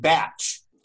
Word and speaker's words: Bach: So B-A-C-H, he pronounced it batch. Okay. And I know we've probably for a long time, most Americans call it the Bach flower remedies, but I prefer to call them the batch flower remedies.